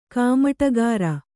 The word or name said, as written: ಕಾಮಟಗಾರ